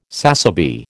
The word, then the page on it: sassaby: American English